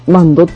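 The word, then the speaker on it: 万灯: いうことで、えー、この矢部市にはですね、矢部市内にはあの京福電鉄が、えー、協力してやっている、あの、関宮温泉万度の湯、万度っていうのは 1 万 2 万の万に、えー、火に蝶って書いて、マンドって読むんですけど、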